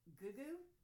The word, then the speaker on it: Gugu: And, I hope I'm pronouncing this correctly, Gugu? G-U-G-U is her first name. Mbatha? M-B-A-T-H-A? – Sounds good to me.